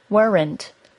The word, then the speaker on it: weren't: Weren't is only one syllable long. I often hear this accidentally pronounced as weren't by my students. Try to say it as one syllable, weren't.